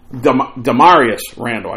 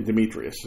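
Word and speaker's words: Damarious: I want to make sure I pronounce his name correctly. I'm looking up how to Demarius Randall. I kept thinking Demetrius.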